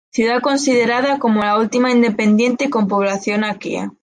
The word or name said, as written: independiente